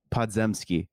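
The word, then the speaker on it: Podziemski: Podzemski.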